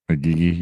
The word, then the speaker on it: Igigi: All of the Igigi? Igigi. – Igigi, yeah.